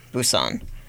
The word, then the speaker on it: Busan: Busan.